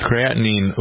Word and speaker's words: creatinine: Who's interested in figuring out what it means to the creatine, I never heard pronounced that right. – Creatinine levels.